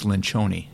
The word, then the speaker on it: Lencioni: it's, it's Lencioni.